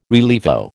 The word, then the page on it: rilievo: American English